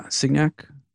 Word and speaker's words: Signac: Yeah, Signac, Port of Rotterdam.